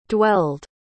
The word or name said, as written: dwelled